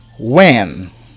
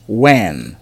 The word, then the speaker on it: when: When. Como se fosse U-E-N. When.